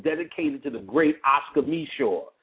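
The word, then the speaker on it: Micheaux: And Oscar Meshaw was the Spike Lee of his day.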